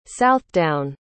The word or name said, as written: southdown